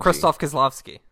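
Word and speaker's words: Krzysztof Kieślowski: How do you pronounce his name? Who did the color trilogy? – Oh, Krzysztof Kieslowski.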